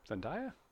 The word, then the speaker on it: Zendaya: I went with Zendaya. – Yeah? – Zendaya? – Yeah.